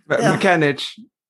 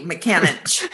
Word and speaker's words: McAninich: And sometimes he does listen to the podcast, but then we also have other people like Mike McAninich. Oh, can never say his name right. – McAninich. – Okay. Right. Thank you. McAninich.